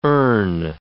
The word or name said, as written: erne